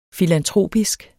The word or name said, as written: filantropisk